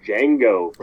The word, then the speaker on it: Django: Gentlemen, the D is silent. I'm going with Django, Ooh.